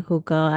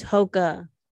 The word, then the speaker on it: Hoka: Not me thinking over here, with doing hookah at Northgate. No, it's Hoka, H-O-K-A.